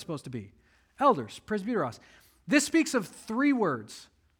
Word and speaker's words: presbyteros: There's elders, and that's the Greek word presbyteros. That's how you pronounce it. I know the English looks like presbyteros. It's just presbyteros.